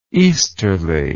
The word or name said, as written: easterliness